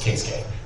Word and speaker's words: Keisuke: And it's even rarer because she's the daughter of Kitsute Itagaki, the mangaka. Oh, you want to talk about this one? – Oh, no, I'm just saying it's pronounced Keisuke.